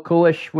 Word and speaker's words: Kulish: And today I have another returning guest, Bill Kulish with Steelike.